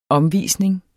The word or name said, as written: omvisning